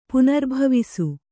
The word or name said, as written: ಪುನರ್ಭವಿಸು